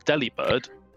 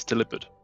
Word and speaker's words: Delibird: Delibird is everyone has been mispronouncing it, and it's Delibird.